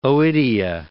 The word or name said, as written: oidia